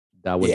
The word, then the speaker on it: Dawodu: Is that how you pronounce that? Dawodu? – Dawodu.